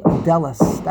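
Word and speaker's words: daled: But the way it's spelled is Dalid Lamid Tuf, which would mean Delas, not Dalid. I don't know why we pronounce Dalid. It's really Delas.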